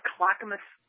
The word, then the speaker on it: Clackamas: Clackamas, Oregon.